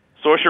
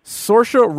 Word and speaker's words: Saoirse: Saoirse Ronan. – Saoirse Ronan is awesome.